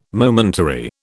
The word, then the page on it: momentary: British English